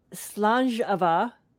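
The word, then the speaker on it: Slàinte mhath: But I'm renaming it Slangeava, which is how you say it in Scottish. It's that word that looks like Slainte Mahath.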